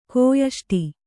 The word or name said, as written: ಕೋಯಷ್ಟಿ